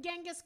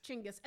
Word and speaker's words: Chinggis: Chinggis, as in Genghis Khan, but it's pronounced Chinggis, as you told me before we left, and it's true.